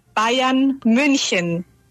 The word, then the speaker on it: Bayern München: El equipo se llama Bayern München, pronunciado en alemán.